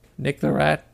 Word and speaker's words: Nick the Rat: It's also phonetic. Nick the Rat. How else can you spell that? N... Ick, the, duh, rat.